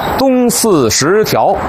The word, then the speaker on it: 东四十条: 还有今天呢咱们来到的这个地方东四十条，这是标准发音啊，但是您得琢磨琢磨这怎么断句呀。